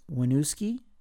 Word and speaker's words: Winooski: Their first show was in, I think it's pronounced Winooski, W-I-N-O-O-S-K-I, in Vermont in 2008.